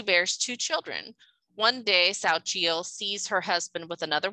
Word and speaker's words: Xochitl: It's spelled X-O-C-H-I-T-L. Mm-hmm. So I would say Xochitl. That's how I would pronounce it. But it's Sao Chiel.